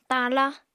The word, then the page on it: tala: apatani